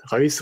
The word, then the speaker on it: Reus: Reus, voilà, c'est beaucoup. – Tu penses beaucoup. Si, au cas où, si tu t'hésites sur la prononciation, tu penses à Rolls-Royce.